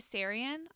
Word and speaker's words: Sarian: But my number two is Bailey Sarian on YouTube.